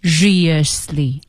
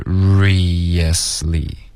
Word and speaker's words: seriously: 如果我读成 seriously 错了对应该是 seriously